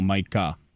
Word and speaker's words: Micah: His name is Micah, M-I-C-A-H? – I didn't ask him to spell it. – Right, but I mean not Michael, Micah. – Micah. – Okay. – So it could be M-I-C-A-H, it could be M-Y-C-A-H.